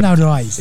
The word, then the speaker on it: Diana Ross: E aí a gente pega e manda duas da Diana Ross. É isso mesmo? Eu tô falando errado? Tô falando certo. – Diana Ross. – É isso aí.